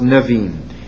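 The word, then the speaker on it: Nevi'im: That's pronounced Nevim.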